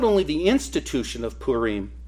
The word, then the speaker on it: Purim: The book's primary theme is to record the institution of Purim, and it's pronounced Purim. I actually looked it up. It's pronounced Purim, and it's an annual festival usually taking place in the months, our months, of February and March.